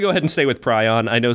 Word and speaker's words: prion: Real quick, there's a little bit of a discrepancy on how that word is pronounced. P-R-I-O-N, sometimes referred to as a prion. – But sometimes pronounced maze. – God damn it. But I looked it up. Prion apparently was the original version of that. However, it is sometimes pronounced now. And also, it fits with the English language because if you look at like stuff that is on that range, like lion, you wouldn't call it a Leon.